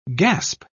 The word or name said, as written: gasp